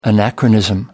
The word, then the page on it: anachronism: American English